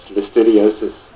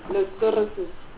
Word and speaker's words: Listeriosis: Chairperson, you should first be able to pronounce the disease properly. Listeriosis. – Good morning. – I'm only trying to help you. – Yeah, no, that's good of you. Listeriosis.